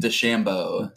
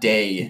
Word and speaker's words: DeChambeau: I think that Bryson DeChambeau. – Pronouncing his name wrong. – How would you pronounce? – Bryson DeChambeau. – De. – Yeah, not day.